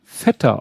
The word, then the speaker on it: Feta: so auf Griechisch basiert, weil mir halt eine Griechin mal gesagt hat, dass dieser Schafskäse Feta ausgesprochen wird. – Mhm. – Und erstmal die gute Nachricht, das habe ich kontrolliert, das kann ich beweisen, das, dem ist auch so.